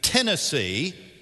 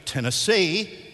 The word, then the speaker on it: Tennessee: If they say Tennessee, put the emphasis on the first syllable, they're obviously from East Tennessee. If they say it correctly, Tennessee, you know that they're from Nashville.